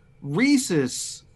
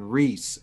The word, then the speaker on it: rhesus: So, but you know, I understand and I agree that I am mispronouncing it, not out of ignorance, but it's out of a habitual laziness that rhesus is harder to say than Reese.